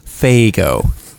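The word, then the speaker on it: Faygo: Sorry, it's pronounced, it's pronounced Fago. – My mistake. It was a umlaut in there. I didn't. – This is a hard F. Very hard.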